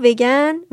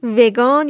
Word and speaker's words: ویگن: وگن وگان یا ویگن گفته میشه تلفظهای متنوعی فارسی داره و یه تلفظ رسمی یا اینکه ترجمه ازش وجود نداره هنوز من بهش میگم ویگن چون به تلفظ انگلیسی نزدیک تره